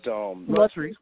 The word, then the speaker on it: Latrice: I don't know if it's Latrice or Patrice, but Latrice with an L. I've been corrected. – I'm sorry, Patrice. – Yes. Yeah. – Latrice with an L. You said it correctly the first time. Latrice.